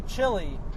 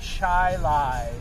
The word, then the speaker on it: Chili: But it can't be chili. – It's spelled like chili. – C-H-I-L-I. But it can't be chili. It's got to be Chi Lai, because right.